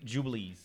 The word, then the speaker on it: Jubilees: They're called the Air Jordan 11 25th anniversary Jubilees. – So they're the actual Jordans. They're actual Jordans. Okay. – First syllable Jew.